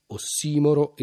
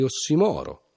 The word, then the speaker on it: ossimoro: Non si tratta, insomma, di uno di quei casi in cui la scelta è tra la pronuncia alla greca e la pronuncia alla latina, come invece rispettivamente in ossimoro e ossimoro, flogosi e flogosi, perseo e perseo.